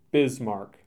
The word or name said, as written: Bismarck